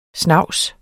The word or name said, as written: snavs